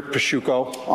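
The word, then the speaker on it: Pasciucco: Attorney Peter Pasciuco on behalf of Kevin Albert.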